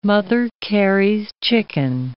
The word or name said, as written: Mother Carey's chicken